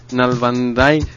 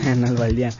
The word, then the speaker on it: Nalbandian: Está muy difícil, Nalbandai. – Exacto, está muy difícil de pronunciar el nombre, Nalbandai. – Pero bueno, ahí está, Rafael Nadal que avanzó a octavos. Fíjate que esto del deporte... – Nalbandian.